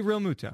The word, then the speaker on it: Realmuto: Well, I thought it's Realmuto. – JT Realmuto. – Yeah, you pronounce Real. – Realmuto. – Like Real. Yeah, Realmuto.